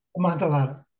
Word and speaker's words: Amontillado: I'm going to say it wrong. You could say it for me. – Amontillado.